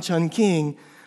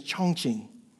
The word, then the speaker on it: Chongqing: And so we look on the map and we don't see a Chungking, but then we look in the travel guide and we find Chungking. We find the correct pronunciation is Chongqing.